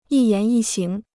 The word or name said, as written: 一言一行